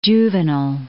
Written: Juvenal

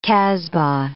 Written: casbah

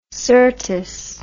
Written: syrtis

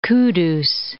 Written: Kudus

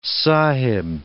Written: sahib

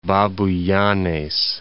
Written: Babuyanes

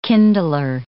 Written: kindler

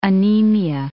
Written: anemia

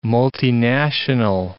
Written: multinational

